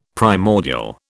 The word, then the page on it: primordial: British English